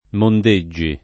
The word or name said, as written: Mondeggi